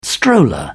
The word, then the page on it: stroller: British English